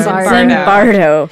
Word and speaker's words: Zimbardo: So obviously we don't agree with Mr. Phil, how do you say his name Zimbardo. – Again? – Zimbardo. – Zimbardo.